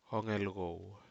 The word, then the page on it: hogeco: Aneityum